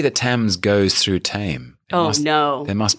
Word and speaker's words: Thames: There are silent letters in it, and you know, yes, Thames. It should almost. How would you spell Thames? – T-E-M-M-S.